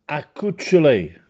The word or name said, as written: Accuccelè